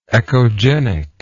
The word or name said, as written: echogenic